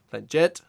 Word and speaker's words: Blanchett: Remember, you notice the pronunciation on that? Blanchett.